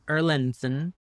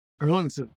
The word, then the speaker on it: Erlandson: I always get nervous saying your last name because there's a lot of ways in which you can't say it, but I would say Erlandson with a soft D, not a hard D. Okay. Well, can we hear you say your name? – Erlandson.